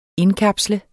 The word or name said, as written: indkapsle